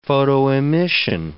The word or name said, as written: photoemission